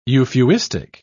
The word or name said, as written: euphuistic